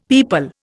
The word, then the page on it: pipal: Indian